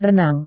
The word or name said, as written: renang